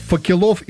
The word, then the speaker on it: факелов: Как правильно говорить? Факелов, факелов или допустимы оба варианта? – Факелов. – Правильно.